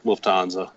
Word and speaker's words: Lufthansa: Lufthansa.